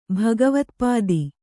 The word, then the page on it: ಭಗವತ್ಪಾದಿ: bhagavatpādi